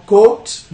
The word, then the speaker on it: cooked: Acá quizás en cooked, cooked, no cooked, but como book, libro, books, yeah, cooked, overcooked. Otra vez, doble consonante, la K y la suena como, lo pronunciamos como T al final, cooked, cooked, cooked, cooked, cooked, overcooked.